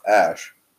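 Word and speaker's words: Æ: And it's pronounced Ash.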